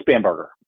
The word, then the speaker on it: Spanberger: That could be Spanberger. Help me out. – I think it's Spanberger. – Spanberger, thank you.